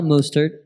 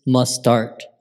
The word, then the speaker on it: Mostert: No, it's not Mostert. It's Mustart. That's how you say his name.